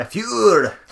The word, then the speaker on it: Feuer: And the producer, Cy Feuer. – I know, his name rhymes with mein Feuer.